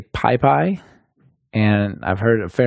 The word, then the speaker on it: PyPI: The official way to say it is PyPI. It's Python Package Index. So, you know, you put the emphasis on the PyPI.